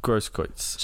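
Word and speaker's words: Großkreutz: Roberto Carlos, Kevin Grobkretz. Don't know. – Grosskreutz. – Something like that. Wayne Rooney, Zlatan. – Did you just pronounce that German B looking thing as a B? – Yeah, I don't know.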